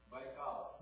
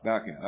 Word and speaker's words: Baikal: We go down and baptize in Lake Baikal. Did I say it right? – Baikal. – Baikal. Okay, that's what I said.